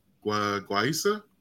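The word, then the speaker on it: guaiya: Chamorro was, I believe it's pronounced Guaisa. – Guaisa. – Guaisa, right? – Yeah. – It looks like Gaia, right? But then it's like.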